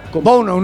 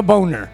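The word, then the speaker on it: Bounou: Es que lo escriben Bono, pero lo pronuncian Bono. – No, no. Bono, no Boner.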